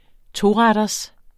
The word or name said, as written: toretters